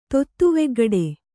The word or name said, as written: ತೊತ್ತುವೆಗ್ಗಡೆ